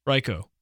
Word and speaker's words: Raikou: Raikou.